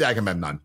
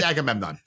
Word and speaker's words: Agamemnon: It's Agamemnon. I cannot not read that when I see it. So I know it's Agemmman. That's not how you say it. That's not how you spell Agamemnon. But it's Agamemnon.